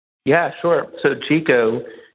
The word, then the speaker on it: Jiko: But now what I'm most excited about is I'm the co-founder and president of GECO, and we are trying to disrupt banking with a pretty unique concept. – And how do you spell that? J-I-K-O. GECO? – J-I-K-O. GECO. It's the Japanese word for self, which is at the center of the experience we're trying to create.